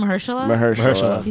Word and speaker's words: Mahershala: Is that how you say it? – Mahershala.